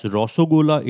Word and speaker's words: Rasgulla: According to this belief, the Roshogolla, pronounced as Rasgulla in Odia, has its origin in the temple town of Puri in Odisha.